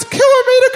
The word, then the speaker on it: kilometre: Why don't SBS newsreaders pronounce kilometre correctly? Sounding the prefix kilo and the unit metre, it is annoying that people from Europe, whom our children might assume understand the metric system, are setting such a rotten example.